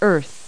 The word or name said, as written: earth